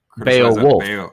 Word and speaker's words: Beowulf: How am I supposed to pronounce it? Somebody Beowulf. Wolf. – Beowulf. Wolf. With the ULF.